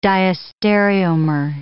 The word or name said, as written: diastereomer